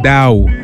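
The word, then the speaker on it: Daal: incoming heat from your friend D-A-A-L. I think that's pronounced Dow.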